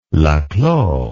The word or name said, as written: Laclos